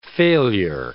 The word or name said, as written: failure